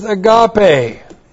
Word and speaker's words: agape: When it says God is love, it says God is agape. A-G-A-P long E. Agape.